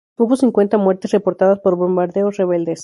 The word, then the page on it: rebeldes: spanisch